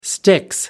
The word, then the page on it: sticks: British English